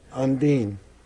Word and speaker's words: Undine: Undine.